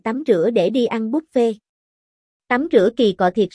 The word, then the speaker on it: buffet: Đi ăn buffet, đọc là buffet theo kiểu tiếng Pháp, chứ không phải buffet theo tiếng Anh đâu nha.